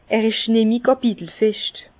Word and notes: Er isch nemmi Kapitelfescht: Bas-Rhin; Herrlisheim

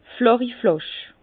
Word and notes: flàchi Flàsch: Bas-Rhin; Herrlisheim

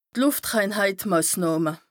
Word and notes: d' Lùftreinhàltmàsnàhme: Bas Rhin

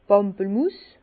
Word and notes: d' Pampelmüss: Bas Rhin; Herrlisheim

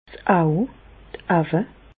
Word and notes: 's Auw, d' Auwe (pl): Bas-Rhin; Reichshoffen

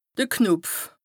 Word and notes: de Knopf: Bas-Rhin; Strasbourg

